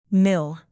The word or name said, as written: mill